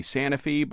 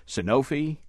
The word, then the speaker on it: Sanofi: You know, there's been a lot of debate here in Boston, even among our own listeners, how to pronounce Sanofi. At the company's U.S. headquarters in New Jersey, they say Sanofi, but the analyst that we just heard on the air said Sanofi.